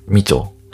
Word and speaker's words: 美酢: まあ、ビールにね、入れたりするっていうのも結構驚きなんですけども、えー、それがまあ、みちょ、えー、美しい酢って書いてみちょって読むんですけど、まあ、それがみちょですね。